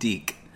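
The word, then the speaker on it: DiC: How do you say it? – Deke. – Deke.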